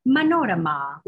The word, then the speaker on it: Manorama: And my name is pronounced Manorama with the accent again on that last the O and the A.